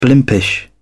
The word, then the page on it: blimpish: British English